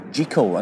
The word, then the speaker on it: Jekyll: Well, some say there's a theory that the pronunciation of Jekyll should be Jekyll, and many Scots pronounce it Jekyll. The thinking, some say, is that Jeek to rhyme with Seek.